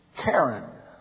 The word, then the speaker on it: Karen: Karen, replied the diplomat's Burmese guide. Karyan, dead stuff, mispronounced the Englishman.